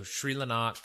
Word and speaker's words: Crljenak: So it's CRLJENAK. Sri Lanak? – Yeah, Sri Lanak is what I was saying as well. So Sri Lanak.